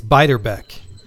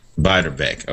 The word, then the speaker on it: Beiderbecke: And I know I'm going to probably mispronounce his last name. Bix Beiderbecke. – I think it's Beiderbecke. – Beiderbecke. Okay. Yeah. There we go.